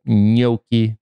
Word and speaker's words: gnocchi: And I pretended I was, but gnocchi, which I think when I'm feeling lazy, I pronounce as gnocchi, but gnocchi is the way to pronounce that.